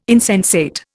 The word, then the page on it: insensate: Indian